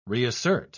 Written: reassert

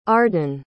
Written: ardon

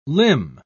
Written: limn

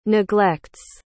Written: neglects

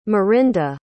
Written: merinda

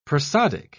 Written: prosodic

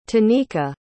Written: tanika

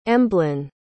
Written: emblyn

